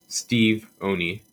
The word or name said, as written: Steve Oney